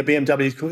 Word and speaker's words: BMW: So in Germany, a BMW is called a BMW because W is pronounced V, like a V.